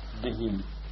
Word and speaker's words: Buddhi: Buddhi. We should pronounce these H's, they're very important. If you say Lucky Buddhas, it's not quite the same meaning.